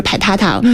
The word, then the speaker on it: 陪塔塔: 刚才也提到就是陪塔塔，我们说的那个发音，它其实是宠物，但是其实它最早的那个前面的发音是来自于老北京话里边的那个排着，就表达的是一种很慵懒放松的这样一种生活态度。